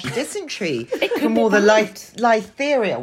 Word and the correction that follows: Listeria: What's it called? That thing you can't do when you're pregnant. All the terrible bacteria that's in all the Listeria. – Listeria. There we go. – Listeria. – Rhymes with hysteria.